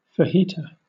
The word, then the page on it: fajita: British English